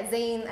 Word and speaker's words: zine: Most people say zine, which is fine, I guess. But yeah, zine as in magazine.